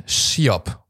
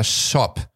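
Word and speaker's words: scop: Beziehungsweise, das ist ein Wort aus dem Altenglischen, das gibt es wirklich und das wird Schiop oder Shop ausgesprochen, was klingt wie der alte Korb.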